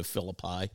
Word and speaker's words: Philippi: I believe that's how you pronounce it. Is that right? Philippi. Yeah, Philippi. And sorry about that.